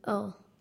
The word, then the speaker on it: Mukwonago: Mukwonago. – Could you repeat that a little slower, please? – Again, Mukwonago.